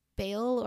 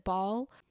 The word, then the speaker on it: Baal: It was after he was asking their god Baal, or I thought you would pronounce it Baal.